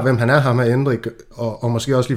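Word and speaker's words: Endrick: Lad os hoppe videre til Endrik. Er det ikke sådan, det skal udtales? Jo, det tror jeg, jo.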